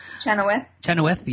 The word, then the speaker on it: Chenoweth: I didn't really watch much of the red carpet, but Kristen Chenoweth. Is that how you pronounce her name? – Chenoweth. – Yes.